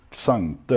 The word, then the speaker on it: Xante: Har det uttalats Xante då ju? Xante, ja. ja. Sante sa vi kanske.